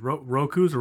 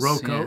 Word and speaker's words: Roko: Roku's or Roko's? Roku's. – Roko's. – Roko. Yeah. Is it Roko? – Roko, yes.